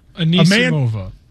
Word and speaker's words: Anisimova: Anisimova. – Anisimova.